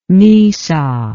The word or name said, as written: missa